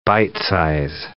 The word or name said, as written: bite-size